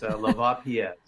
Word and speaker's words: Lavapies: Well, that's how it looks like. It's Lavapies.